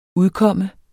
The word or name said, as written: UDKOMMER